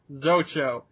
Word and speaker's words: Zacho: All right, Joshua said two things about the Zacho broadcast. Number one, Zacho, the ship, is presumably pronounced with two hard O's, Zocho, since the name is Japanese in origin.